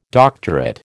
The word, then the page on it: doctorate: American English